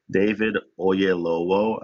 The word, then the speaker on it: Oyelowo: David Oyelowo, I think his name is. And that's how you pronounce the last name.